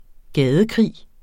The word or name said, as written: gadekrig